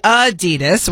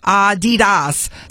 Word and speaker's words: Adidas: And hey, did you know Americans pronounce Adidas wrong? It's supposed to be Adidas with the slight emphasis on the first syllable, not Adidas, stressing the second.